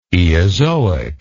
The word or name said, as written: eozoic